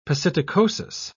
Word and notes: psittacosis: British English

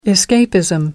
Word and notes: escapism: American English

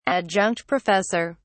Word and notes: adjunct professor: British English

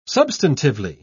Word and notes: substantively: British English

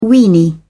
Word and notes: weeny: British English

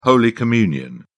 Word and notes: holy communion: British English

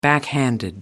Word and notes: backhanded: American English

